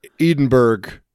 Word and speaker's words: Edinburgh: You're supposed to say Edinburgh or Edinburgh. – Edinburgh.